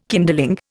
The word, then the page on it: kindling: Indian